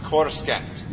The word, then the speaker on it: Coruscant: I'm taking these people to Coruscant. Where are you taking them? – Waitmaster. We've discussed this before. It's pronounced Coruscant.